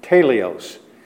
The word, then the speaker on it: teleios: The Greek word for perfect there is teleios. We would spell it T-E-L-E-I-O-S, teleios.